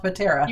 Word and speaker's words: Potere: Potera. – You got it. – Tell us a little bit about what your business is. – Sure. Thank you so much for having me. I am excited. And Potera Coaching is a business that I launched in September of 2020.